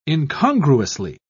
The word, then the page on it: incongruously: British English